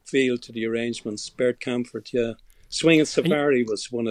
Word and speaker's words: Kaempfert: You also have things in like Bert Kaempfert. Is that how it's pronounced? – Oh, Bert Kaempfert. Yeah, he was a German orchestra, mainly brass, some brass and some strings.